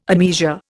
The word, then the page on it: admeasure: Indian